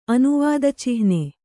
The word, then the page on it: ಅನುವಾದಚಿಹ್ನೆ: anuvādacihne; kannada